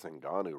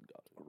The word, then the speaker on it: Ngannou: Hold on, you said his name wrong. You mean number 10 heavyweight in the world by the WBC, Francis Ngannou.